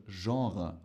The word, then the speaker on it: Genre: Das Wort wird Genre ausgesprochen.